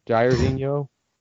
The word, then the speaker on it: Jairzinho: Jairzinho? – Yeah.